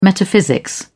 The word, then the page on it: metaphysics: British English